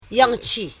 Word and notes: jachi: ja.chi

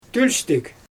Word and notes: tølʲstɪg: Xyzyl

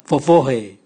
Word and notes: vovohe: Raga

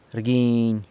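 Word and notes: rguiny: San Lucas Quiaviní Zapotec

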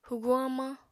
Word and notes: hwguñ hamañ: apatani

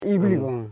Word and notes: ibil: i.bil

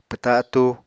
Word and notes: pwta atúu: Apatani

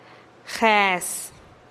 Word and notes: jes: TdVZ

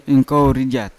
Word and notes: inkovo rjat: Yawa

